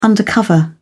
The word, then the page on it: undercover: British English